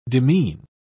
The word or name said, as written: demean